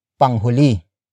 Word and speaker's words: panghuli: If Wilson ends up last in the race, you can say panghuli.